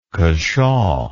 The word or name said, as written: cashaw